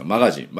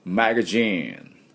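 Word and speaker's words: magazine: そこで、あの今回のですね、雑誌で、雑誌って英語でなんていうのかと、もちろん知ってると思いますが、マガジン、でも発音が違うんですね。どんな発音かっていうと、マガジーンっていうふうに発音します。で、この A の音がミャーの音ですね。だからマガジーン、ミャーの音です。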